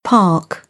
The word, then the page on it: park: British English; ɑː